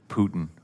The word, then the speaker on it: Putin: Putin.